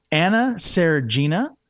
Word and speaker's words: Seregina: Anna Seregina and Kyle Mizono, welcome to the broadcast.